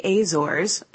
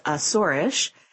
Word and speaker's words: Azores: Most European airlines only fly to the Azores, actually pronounced Azorish, in the summer months, despite the fact that the weather hovers around 60 degrees Fahrenheit all year.